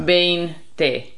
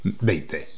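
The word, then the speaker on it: veinte: Bein te. – Yeah. And remember that the V is a B sound. So yes. You're not saying bein te. You're saying bein te.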